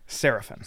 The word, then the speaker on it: Serafin: Serafin.